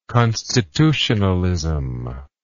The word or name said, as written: constitutionalism